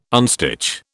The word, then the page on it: unstitch: British English